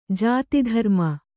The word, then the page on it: ಜಾತಿಧರ್ಮ: jāti dharma